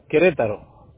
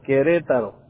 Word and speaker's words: Querétaro: Okay, Querétaro. – ¿Cómo se escribe? – It's Q-U-E-R-E. – Okay. Mm-hmm. – T-A-R-O, Querétaro.